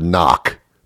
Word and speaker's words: knock: Who came up with the word knock beginning with a K? Why, there's no reason in the world why it wouldn't begin with an N. No one says knock. That's not how you say it. So what's the K doing there?